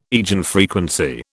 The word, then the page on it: eigenfrequency: British English